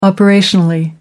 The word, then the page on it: operationally: American English